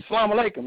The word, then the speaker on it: salam alaikum: And I used to tell him, man, say it. And he used to say, salam alaikum, man. That's how I learned how to say it from him, because I was so embarrassed to say, how you say that thing again, you know. But that's how I learned it.